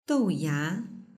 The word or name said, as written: douya